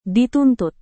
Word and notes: dituntut: Indonesian

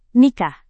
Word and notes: nikah: Indonesian

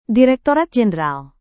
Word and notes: direktorat jenderal: Indonesian

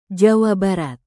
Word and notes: jawa barat: Indonesian

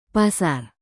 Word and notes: pasar: indonesian

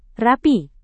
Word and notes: rapi: indonesian

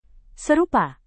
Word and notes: serupa: Indonesian

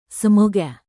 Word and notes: semoga: Indonesian

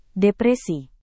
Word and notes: depresi: Indonesian

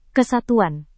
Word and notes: kesatuan: indonesian

